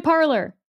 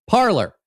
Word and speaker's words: Parler: I'm going to say Parler. – It's clearly Parler. – Okay, Parler.